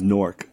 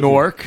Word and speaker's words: Newark: Correct New Jersey pronunciation, by the way, is Newark. – Thank Newark? – Say Newark.